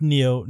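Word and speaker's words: Niyo: When I thought it was John Nio. Is it Neo? – Neo. – I've been saying it wrong this whole time.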